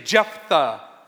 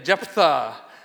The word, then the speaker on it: Jephthah: Everybody say Jephthah. Yeah, you got a little bit of the lisp thing. Jephthah, okay? And that's how we're going to pronounce it here.